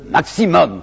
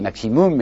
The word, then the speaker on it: maximum: Ces gens demandent le maximum. Vous savez, aujourd'hui on dit maximum, mais ils disaient maximum, alors je prononcerai comme eux.